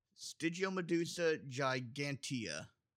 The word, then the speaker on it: Stygiomedusa gigantea: Stygiomedusa gigantea.